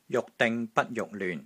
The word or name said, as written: JUK6 DING6 BAT1 JUK6 LYUN6